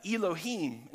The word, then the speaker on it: Elohim: And so when we're talking about the divine counselor, when we're talking about this heavenly court, the original Hebrew word is Elohim. And it's spelled like this right here, Elohim, E-L-O-H-I-M, all right, Elohim.